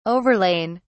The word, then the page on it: overlain: British English